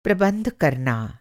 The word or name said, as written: प्रबंध